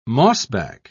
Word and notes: mossback: British English

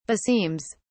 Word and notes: beseems: British English

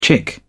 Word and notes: chick: British English

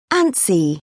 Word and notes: antsy: British English

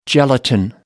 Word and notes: gelatine: American English

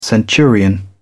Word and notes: centurion: British English

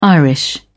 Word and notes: irish: British English

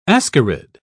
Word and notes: ascarid: British English

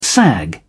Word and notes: sag: British English

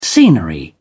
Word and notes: scenery: British English

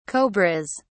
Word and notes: cobras: British English

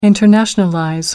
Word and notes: internationalize: American English

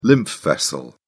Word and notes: lymph vessel: British English

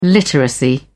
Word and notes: literacy: British English

